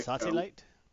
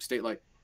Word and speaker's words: State Light: Is that like, Sate Light? – Nope, State Light. I'm going to say it like that.